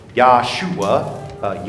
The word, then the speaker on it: Yahshua: Yeah, and there you see, call his name Jesus, which in Hebrew would be Joshua, or more pronouncing correctly in the Hebrew tongue, Yahshua, Yahweh is salvation.